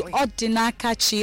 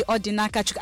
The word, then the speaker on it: Odinakachukwu: Odinakachi, Odinakachukwu. As long as you're not asking a question. – Okay. – A lot of times I meet people and they say, Odinakachi. No, you're not asking me.